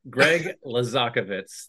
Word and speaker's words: Greg Laszakovits: You're very close. I'll give you an A for effort. Greg Laszakovits. Thanks.